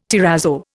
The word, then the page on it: terrazzo: Indian